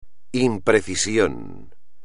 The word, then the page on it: imprecisión: espagnol